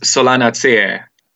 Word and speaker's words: Solanaceae: It's called Solanaceae, and that's and that's a botanical name basically.